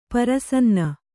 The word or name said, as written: ಪ್ರಸನ್ನ